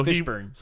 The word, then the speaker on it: Fishburne: Well, it's not spelled B-U-R-N. – It's not? – No. – It's spelled B-E-R-N? – It's like born. – Oh. – Born. – Is fish born. Born. So Fishburne.